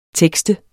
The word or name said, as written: tekste